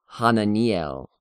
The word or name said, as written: Hananiel